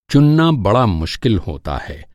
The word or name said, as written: चुनना